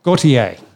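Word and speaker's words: Gotye: Goit, is it Goit? – Gautier.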